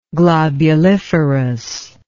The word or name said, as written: globuliferous